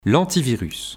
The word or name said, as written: L'antivirus